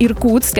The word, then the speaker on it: Иркутск: как Иркутск, and Rob, you did a great job of pronunciation, Иркутск и Красноярск.